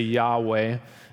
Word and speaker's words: Yahweh: Beginning in about the 4th century BC, the Jews considered this name of God, we're going to say Yahweh is the right pronunciation here.